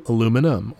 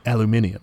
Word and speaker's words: aluminium: And then you got to smelt that alumina into aluminum, or as the British say, alu- aluminium. Aluminium.